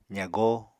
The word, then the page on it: zagó: apatani